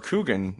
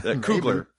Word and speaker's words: Coogler: Coogan. – Yeah. – Am I saying that right? Is that his last name? – I don't know. – Coogler.